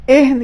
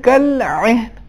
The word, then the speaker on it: عین: اس میں حرف عین عربی زبان میں حلق کے وسط سے ادا ہوتا ہے, وسط حلق سے, یعنی اس کا عربی لہن ہونا چاہیے. عین یہ اردو تلفظ ہے, عربی تلفظ میں کل عین,